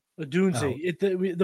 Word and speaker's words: Odunze: But at the combine, Roma Dunze feels like, I hope I'm saying it right, Odunze or Dunze. I'm going to figure that Odunze. The pronunciations are in the the interview schedule.